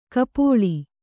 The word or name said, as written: ಕಪೋಳಿ